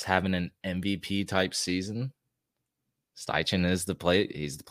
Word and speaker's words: Steichen: Steichen. It could be pronounced Stetchen, but we're going to go with Steichen.